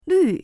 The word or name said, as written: lv4